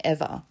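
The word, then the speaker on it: Ewa: So this is a Patreon location request for the next person on the list, which is Patron Eva. That is how it's pronounced. It's E-W-A.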